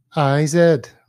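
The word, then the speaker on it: Corteiz: What is it? Cortez. I'm looking at it. – Well, it's Cortiz, right? – Yeah, but then on the website, it's spelled CRTZ.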